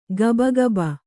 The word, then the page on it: ಗಬಗಬ: kannada